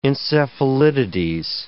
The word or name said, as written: encephalitides